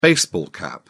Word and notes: baseball cap: British English

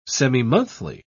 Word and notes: semimonthly: British English

